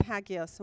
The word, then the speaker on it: Hagios: H A G I O S, Hagios.